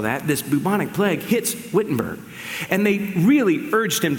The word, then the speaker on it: Wittenberg: We would say Wittenberg, but they pronounce their W's as V's, so I'm going to be proper and say Wittenberg, Germany.